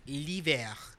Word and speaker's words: l'hiver: It's like it's like the word for winter in French is l'hiver. So you can just say Unilever, but just spread straight up.